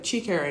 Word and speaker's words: Chikara: And I was like, oh, yeah, my name's like Chikara. And he's like, oh, Shakira.